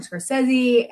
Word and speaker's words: Scorsese: And of course, you have Martin Scorsese. And am I pronouncing that last name right? – Yeah.